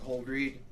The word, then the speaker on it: Holdreed: My name is Doug Holdreed, spelled like you hold a book and read it, H-O-L-D-R-E-E-D.